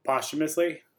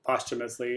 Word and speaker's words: posthumously: And I think you would probably say it like posthumously. – Oh, thank you. I cannot pronounce that word to save my life. – It's a hard one. – I can't. There's certain words, like I get stuck going a certain direction and there is no changing it. And I know it's wrong. – There's a couple different pronunciations on it, but like posthumously is probably one of the ways that you try and pronounce it.